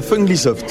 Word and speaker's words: Funglisoft: Alors, c'est la Funglisoft. – Funglisoft. – Fun comme fun en anglais, et Glee soft.